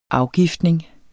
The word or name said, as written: afgiftning